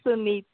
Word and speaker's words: Sunita: Okay, so yeah, the pronunciation was quite right. You can call me Sun. It is Sunita.